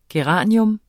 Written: geranie

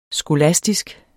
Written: skolastisk

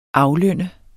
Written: aflønne

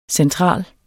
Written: central

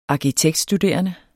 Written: arkitektstuderende

